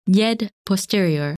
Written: Yed Posterior

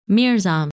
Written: Mirzam

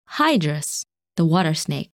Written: Hydrus

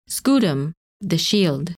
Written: Scutum

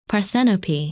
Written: Parthenope

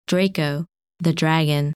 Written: Draco

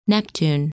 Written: Neptune

